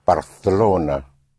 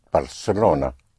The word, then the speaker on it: Barcelona: There's a slight difference in pronunciation with so-called Castilian Spanish and the pronunciation in parts of Spain, where the C in front of an E or I is pronounced like a TH in English, like in Barcelona. Well, that's how you will pronounce it in Castilian Spanish in parts of Spain, but everywhere else it will be pronounced Barcelona, like an S.